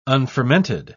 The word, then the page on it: unfermented: British English